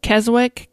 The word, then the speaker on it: Keswick: It's spelled like Keswick, K-E-S-W-I-C-K, but I believe it's pronounced Keswick. I may be wrong, but I think that's how it's pronounced.